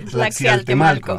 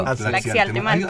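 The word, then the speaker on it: Tlaxialtemalco: Es un poco difícil de pronunciar. Tlaxiatemalco. Tlaxiatemalco. Ah, Así es.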